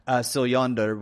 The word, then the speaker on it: Siljander: So what Siljander has done, or Siljander, Siljander would be the Swedish pronunciation of his name.